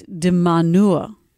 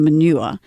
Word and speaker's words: de Minaur: Alex de Minaur. No, I'm not pronouncing that. – That's his name? – D-I-M-A-N-U-R. – De Minaur. – Or not Minaur. Let's not call him Manure, is still in.